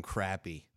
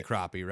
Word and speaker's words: crappie: Well, that's the way it's pronounced up here in the— it's supposed to be crappie, right? It's crappie. – No one's gonna call it a— well, in the south it's crappie, and I kind of adopted that, and I've pronounced it that way for 40 years.